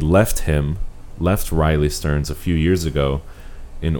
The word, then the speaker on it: Riley Stearns: It's like Riley Streams, I believe. Let me see. Ba-ba-ba. And this is somebody who... Yeah. Oh, Riley Stearns.